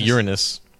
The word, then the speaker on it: Uranus: But Daddy, Uranus isn't going to let them do that. So that is my horoscope. – Wow. Well, I believe it's pronounced Uranus. – Yes.